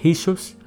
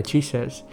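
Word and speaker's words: Jesús: mình sẽ phải đọc là là Jesus là nãy mình nói là chữ J là phải đọc là chữ H đúng không là phải đọc là Jesus chứ không phải là Jesus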